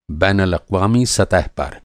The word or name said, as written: बैनालाक़्वामी